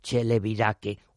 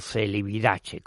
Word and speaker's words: Celibidache: que es don Sergio Celibidache, o Celibidache, como prefieran pronunciarlo.